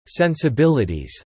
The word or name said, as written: sensibilities